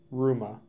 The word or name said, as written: Ruma